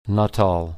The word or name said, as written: Nuttall